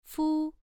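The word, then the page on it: fu: tone 1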